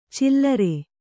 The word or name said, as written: ಚಿಲ್ಲರೆ